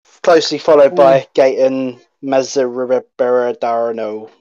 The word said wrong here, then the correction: Matarazzo: However you say his name. – Matarazzo. – That's it. There you go.